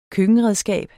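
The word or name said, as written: køkkenredskab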